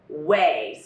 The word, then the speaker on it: OUAI: Quick pause to talk about bad and good hair days that are on the way with our lovely sponsor, Way, spelled O-U-A-I, pronounced Way.